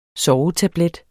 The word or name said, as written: sovetablet